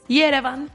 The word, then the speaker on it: Yerevan: Yerevan.